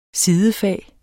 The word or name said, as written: sidefag